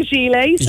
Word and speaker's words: gilets: How do you pronounce it? Gilets? I always fall over that word. What is it? – Yeah, so we say gilets.